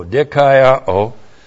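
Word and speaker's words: Dikaiao (δικαιόω): Here's the verb, D-I-K-A-I-O, long O, Dikaiao.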